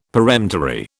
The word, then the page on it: peremptory: British English